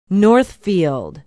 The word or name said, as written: northfield